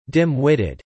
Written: dim-witted

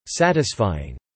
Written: Satisfying

